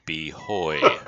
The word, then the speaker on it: buoy: But, you know, three comments, five comments later, some guy has to jump in and say, the proper pronunciation of that word is behoy. Behoy. But when I was in the U.S. Navy, we pronounced it buoy.